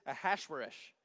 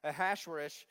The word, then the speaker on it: Ahasuerus: This is Ahasuerus. That's the way the Hebrews pronounce his name. So the Jews that were living there at the time pronounce his name Ahasuerus, but Xerxes is how the Greeks pronounced it.